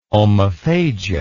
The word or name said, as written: omophagia